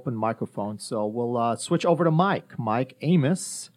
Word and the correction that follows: Ames: I said that right, right? – Ames. – That's okay. Ames.